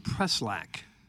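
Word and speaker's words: Preschlack: Another MLB outside counsel, Ben Walker, compelled Diamond CEO David, you pronounce it Preshlack? – I've always said Preshlack. – So the CH is silent? – I think it's Preshlack.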